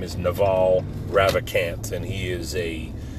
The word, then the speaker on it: Naval: It's the Naval podcast. I know. His name is spelled N-A-V-A-L, like naval, like the Navy, naval.